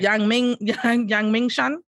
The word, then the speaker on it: Yangmingshan: I know you didn't say it right. – Yangmingshan. Yang Ming, Yangming, Shan.